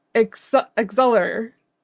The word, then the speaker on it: Xolor: I actually asked Ray how to say it because they spell it X-O-L-O-R, kids. And he said, you say it like you would say Xavier. So like Xavier from X-Men. So it's like Exceller kids.